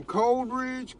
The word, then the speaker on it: Coleridge: Coleridge.